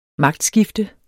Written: magtskifte

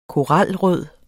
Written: koralrød

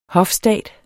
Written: hofstat

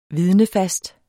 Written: vidnefast

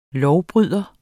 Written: lovbryder

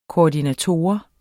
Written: koordinator

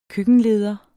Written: køkkenleder

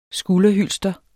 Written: skulderhylster

